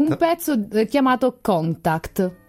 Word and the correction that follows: Kontakte: Ho sbagliato l'accento. – Ma no, ma chissà, no, perché allora, questo non lo so neanche, però in tedesco sarà Kontakte, qualcosa del genere. – Kontakte.